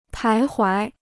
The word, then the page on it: 徘徊: pái huái